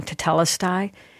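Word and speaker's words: tetelestai (τετέλεσται): I think I pronounce it, correct me if I get it wrong, tetelestai. – That's how I say it, yeah.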